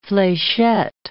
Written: flechette

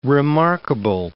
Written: remarkable

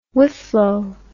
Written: whifflow

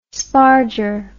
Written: sparger

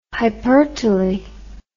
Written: hypertely

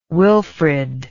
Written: wilfred

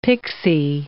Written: pixy

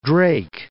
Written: Drake Passage

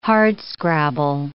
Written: hardscrabble

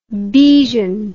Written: bejan

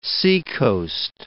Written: seacoast

